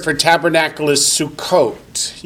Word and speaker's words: Sukkot: You can spell it S-U-K-K-O-T-H or S-U-C-C-O-T-H. Or you can spell it Fred. So long as you look at it and say Sukkot. So that's how you transliterate Hebrew letters, is whatever makes you say Sukkot.